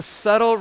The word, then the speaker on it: subtle: It's a subtle? I just pronounced the B. It's a subtle rebellion.